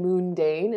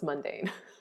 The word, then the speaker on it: mundane: But I also think it is important in addition to doing that to try and turn even boring and mundane, is that how you say it? No, it's mundane.